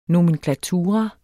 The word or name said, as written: nomenklatura